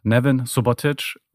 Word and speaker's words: Neven Subotić: Also ich sage immer Nevin Subotic und zwar Betonung auf gar nichts.